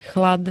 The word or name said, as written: chlad